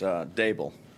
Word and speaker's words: Daboll: Yeah, so it's Daboll. D like table.